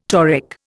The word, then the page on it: toric: Indian